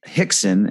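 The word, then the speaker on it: Rickson: But Royce Gracie and his half-brother, Hickson, and by the way, these are spelled with R's, but they're pronounced with H's.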